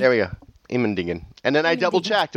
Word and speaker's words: Immendingen: I went to the Immendingen test facility. And is that really how you say that, or did you mutilate that? – Nope, that's how they say it.